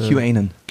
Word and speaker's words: QAnon: Canon-Fans, oder Kanan, Kanan, weißt du die für? – QAnon. – QAnon spricht man das? Ja, ich glaube.